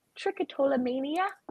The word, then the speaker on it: trichotillomania: Would you also help people who suffer from trichotillomania and other picking disorders? – Yeah. – Did I pronounce that right? – Yeah. Trichotillomania? Yes.